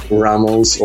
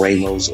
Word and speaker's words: Ramos: Well, my name is Johnny Ramos or Ramos or whatever you how do you, however you pronounce it in English.